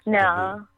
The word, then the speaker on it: debut: so you don't pronounce a T, right? So, debut.